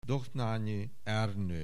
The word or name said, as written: Dohnányi, Ernő